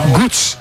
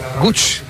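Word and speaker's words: Łódź: ¿Sabes cómo se pronuncia Lodz? Te lo voy a decir, es que es muy difícil, Borja. Gutz, venga. – Gutz. – Lutz. – Increíble. Gutz, Gutz, como que si con W. Gutz.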